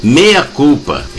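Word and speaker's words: Mea Culpa: Mas vamos ouvir aqui Andréia Dutra, música dela, do seu álbum Jamba de 2012, Meia Culpa. É, vocês podem estar estranhando, mas a pronúncia é essa, Meia Culpa, M-E-A.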